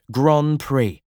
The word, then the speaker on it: Grand Prix: In 1958, he was driving so well that he earned a place in the New Zealand Grand Prix, pronounced Grand Prix, the most important race in the country.